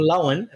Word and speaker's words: Lohan: If we just had Lindsay Lohan blows everyone, or Lohan, apparently as it's meant to be pronounced.